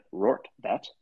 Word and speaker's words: Rortvedt: On Sunday, when the Yankees traded for Josh Donaldson and Isaiah Kiner-Falefa and Ben Rortvedt, getting that right. If you haven't heard his name, that's how you say it, according to Baseball Reference.